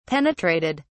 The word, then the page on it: penetrated: British English